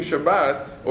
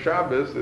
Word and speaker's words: Shabbos: Right, and so these are also part of keeping Shabbat, or Shabbos, as I pronounce it, and so should you.